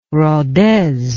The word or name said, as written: rodez